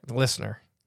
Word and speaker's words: Leisner: Not how you say his last name. – How do you say it? – Listener. – Listener? Mm-hmm. It looks like Leisner.